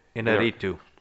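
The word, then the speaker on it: Iñárritu: Alejandro G. – Inarritu. – Yeah, that's it.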